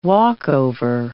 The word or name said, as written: walkover